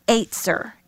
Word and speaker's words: ezer: And that word helper is a noun, and it's the word E-Z-E-R, but it's pronounced Aitzer in Hebrew.